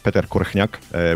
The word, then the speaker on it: Korchnak: So my name is Peter Korchnak, as you mentioned. In Slovak, I pronounce it Peter Korchnak, which the H sound is hard to pronounce for English speakers.